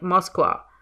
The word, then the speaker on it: Moskva: Actually, while I was recording this, I paused in the middle of the story to look up how to pronounce Moscow in Russian, because I figured that Gino wouldn't pronounce it Moscow even when he's speaking English. He'd say it like a Russian does in Russian, which is something like Moskva. And yes, I probably butchered that.